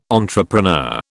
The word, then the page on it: entrepreneur: British English